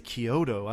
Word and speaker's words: Chiodo: It's spelled like Chiodo, but it's Chiodo, yeah. – I think they say Kyoto.